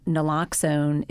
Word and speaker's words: naloxone: It's called uh, naloxone.